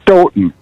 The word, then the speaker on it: Stoughton: You actually, the second time you said it right, Stoughton. They pronounce okay. it Stoughton.